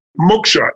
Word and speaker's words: moksha: By the way, once you have your Dharma, it leads, they believe, to what they call moksha. M-O-K-S-H-A is how it's spelled.